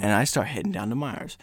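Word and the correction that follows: Meijer: It's pronounced Meyer. – Huh? What did I say? – You said Myers.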